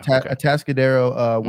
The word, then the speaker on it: Atascadero: I say that wrong every time. Yeah, Atascadero, which I lived 20 minutes down the road, down the highway from him.